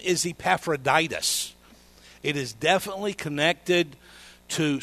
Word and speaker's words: Epaphroditus: But his name is Epaphroditus.